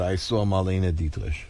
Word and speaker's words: Dietrich: I've never heard that pronunciation. – Well, you don't speak German. Dietrich.